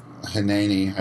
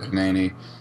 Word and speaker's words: Hineni: It's Hineni, I believe is how you say it. Hineni, I'm ready, my lord, is the chorus.